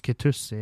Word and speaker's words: Kitussi: For han Trond Kitussi, det uttales Kitussi, jeg har sjekket.